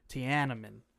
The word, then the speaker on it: Tiananmen: No, it's actually, because I looked it up, the A-N in the middle is a long A-N. So it's like Tiananmen.